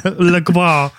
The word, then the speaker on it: La Croix: La Croix. You have to say it like that.